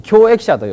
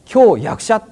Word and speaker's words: 教役者: 教えるという字に、ちょっと手話の通訳やりにくいんですが、教えるという字に役、役場の役に物と書いて、これ教益者と読むんですけれども、でホテルの呼び出しなんかですとですね、これ教益者とはだいたい読めないんですね。教役者っていうんですね。